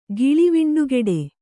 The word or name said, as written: ಗಿಳಿವಿಂಡುಗೆಡೆ